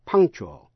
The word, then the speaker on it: punctual: Âm c trong chữ punctual cũng phát âm như k. Thân lưỡi chỉ để vào vị trí sau hàm x, punk, chỗ cúa mềm, nhưng không phát âm k thành tiếng. Rồi tiếp ngay sang chual. Quý vị nghe lại, punk. Punctual, punctual, punctual, P-U-N-C-T-U-A-L, punctual, là đúng giờ.